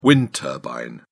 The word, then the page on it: wind turbine: British English